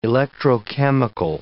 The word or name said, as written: electrochemical